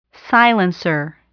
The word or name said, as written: silencer